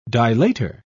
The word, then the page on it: dilator: British English